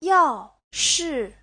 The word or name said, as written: yao4shi4